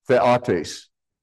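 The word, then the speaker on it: theotes (θειότης): In Romans 1:20, the bottom word, which you see, theotes, and in the top word, I mispronounced it, it's the, with a short e, theotes.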